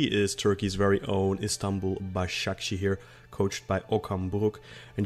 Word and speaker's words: Başakşehir: So I think that could be the way the group finishes up, and it could be a battle for third between Başakşehir and Wolfsburger. So apologies if I've murdered the pronunciation of that there. – That's fine. Başakşehir, but you...